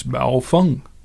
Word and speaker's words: Baofeng: and saw what the common pronunciation in the ham community was. And from what we saw, it's Baofeng.